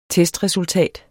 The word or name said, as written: testresultat